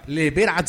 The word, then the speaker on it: Live It Up: De la canción, ¿cómo es que se pronuncia? ¿Cómo es? – Live It Up. – Live It Up, sí, señores.